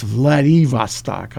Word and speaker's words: Vladivostok: Notice it's pronounced Vladivostok, I found out.